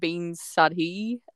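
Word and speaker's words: bean sídhe: And the old Irish word for banshee is actually pronounced like bean sadhi, and then the Gaelic term is like bansith, and both of those translate into woman of the fairies.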